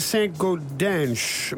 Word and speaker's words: Saint-Gaudens: Je moet volgens mij zeggen Saint-Gaudens, met een S. – Kunnen we misschien al eens een tweetalige Brusselaar vragen? Saint-Gaudens, is dat goed of zo uitgesproken? – Ik zou ook denken Saint-Gaudens, ja.